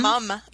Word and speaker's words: mom: You said paleo mum. Yeah. – So here's a fun Canadian fact for you. We spell it M-O-M, but we pronounce it M-U-M.